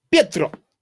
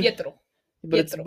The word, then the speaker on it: Pietro: That's a T, not a D. It'd be Pietro. It'd be Pietro, not Pietro.